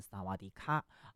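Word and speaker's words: 萨 瓦 迪 卡 (สวัสดีค่ะ): 你一定很熟悉，因为如果加上卡，就是萨瓦迪卡，你好，对不对？啊，但其实呢，这个泰语哈、啊、似乎有这个阴性阳性的这个区别，女生啊讲你好是萨瓦迪卡啊，但是男生呢啊这个发音要是萨瓦迪 club 啊。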